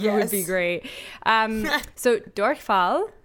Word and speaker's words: Durchfall: Durchfall. Have you ever heard of this? – Durchfall? No. – Well, you wouldn't because you're mispronouncing it once again.